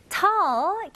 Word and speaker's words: tall: 근데 이거는 한국식 발음이고 영어로 사용하실 거면 이 입술이 동그랗게 되면 안 되고 턱 내리는 느낌입니다. 그래서 tall. Tall. Very good. 이렇게. 그리고 중간 모음 소리를 조금 더 길게요.